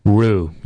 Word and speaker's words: Rue: Rue. You slur the R and the Y together, and there you have it.